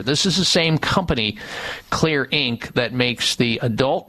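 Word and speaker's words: Xlear: There is a nasal spray on the market called Kids Clear. Clear is spelled with an X, X-L-E-A-R.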